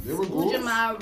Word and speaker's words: Ujamaa: And then you got the Rochester Kwanzaa Coalition presents Ujamaa Rising Live at WOC Collective Space. Did I say that right? – Yes. So